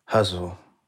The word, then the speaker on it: ghazal: And I'm pronouncing it that way, but it's spelled G-H-A-Z-A-L. – Yes, yeah, it's sort of more guttural. Huzzle, or like huzzah.